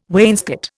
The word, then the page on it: wainscot: Indian